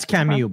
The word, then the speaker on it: Camus: That's Camus, by the way, for Pierre. That's French. – That's what? – How to say his last name is Camus.